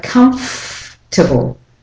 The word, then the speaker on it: comfortable: For example, comfortable, it's not comfortable, but comfortable. The or syllable is dropped completely and you go straight from the F to the T.